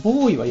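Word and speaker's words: 野郎: で、えっ、ー、と、漫画ね、あの、先週おすすめの漫画ということで、ハイパー戦士ガンダムボーイをね、えー、まあ、ガンダムボーイ、ボーイはやろうって書くんですけど、漢字でやろうと書いてボーイなんですけど、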